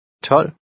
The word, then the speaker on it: tolv: Also note, a final V is usually silent or barely pronounced. Listen and repeat number 5. – Tolv.